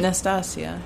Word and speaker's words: Nastassia: Nastasia. – Yeah, I say Nastasha, and so people. – It's one syllable less.